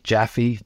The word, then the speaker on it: Jaffe: I think just at my website, just Nick Jaffe. Jaffe's an unusual name. It's spelled J-A-F-F-E.